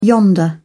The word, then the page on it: yonder: English; British English